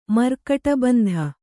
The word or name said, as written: ಮರ್ಕಟಬಂಧ